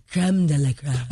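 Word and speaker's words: crème de la crème: It's creme de, no, yeah, it's creme de la creme.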